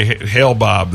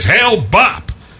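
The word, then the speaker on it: Hale-Bopp: I was getting a big bang out of when that Hale Bob comet was out there, and the people would mispronounce it. They would say Hale Bob. They said the Hale Bob comet. He would say, "It's Hale Bop."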